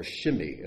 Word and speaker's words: Shimei: Behold, with you is Shimei, or Shimi, as it's pronounced in Hebrew, son of Gera, the Benjamite from Bahurim.